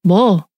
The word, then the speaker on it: 뭐: If you are going to say what, you say 뭐. You know? – Oh, I get it. It's actually pronounced as M-O-R-E, more, without the R.